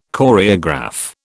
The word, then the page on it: Choreograph: British English